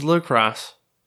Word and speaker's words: La Crosse: Well, I'm glad I pronounced it right. Or is that La Croix? No, that's IX, right? – No. It's La Crosse.